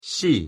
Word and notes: 씨: Korean

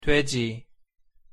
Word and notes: 돼지: Korean

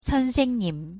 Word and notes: 선생님: Korean

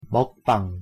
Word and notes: 먹방: Korean

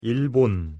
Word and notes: 일본: Korean

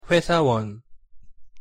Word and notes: 회사원: Korean

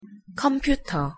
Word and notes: 컴퓨터: Korean

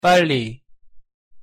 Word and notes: 빨리: Korean

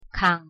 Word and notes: 강: Korean

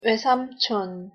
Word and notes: 외삼촌: Korean

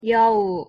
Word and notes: 여우: Korean

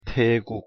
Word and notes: 태국: Korean

